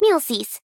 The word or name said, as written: Muelsyse